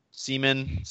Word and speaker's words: Siemian: Siemian? – Yeah. Siemian, yep.